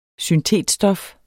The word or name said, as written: syntetstof